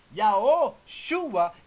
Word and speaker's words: Yahushua: Yahushua is four syllables.